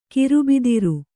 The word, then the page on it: ಗಿರಬ್ದಾರಿ: girabdāri